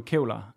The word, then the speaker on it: kævler: Jeg kan godt lide den jyske udtryk, den, den jyske udtale af, af kævler. Af kævler? – Ja, så, jamen, det er sådan, det udtales.